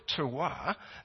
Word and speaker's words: terroir: Terroir? Yeah, thank you. Thank you, Sandra. Terroir. What that means is it's the type of soil that the vine is growing in. And if it's got a good terroir,